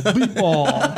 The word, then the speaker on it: Betebol: Beep ball. – Beep ball? – Beep ball. – Spell it. – Beep ball. – How do you spell that? – B-E-T-E-B-O-L. Beep ball.